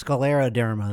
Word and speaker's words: scleroderma: Sclero dis, no, scol- it's scleroderma. That's what it is.